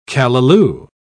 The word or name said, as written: callaloo